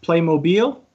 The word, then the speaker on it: Playmobil: So because of my American pronunciations, before we get too far, it is Playmobil, right? Not Playmobil? – Yeah, actually, the brand name is indeed pronounced Playmobil.